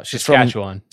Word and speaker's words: Saskatchewan: How do you say that? – Saskatchewan. – Saskatchewan. I never know how to say that.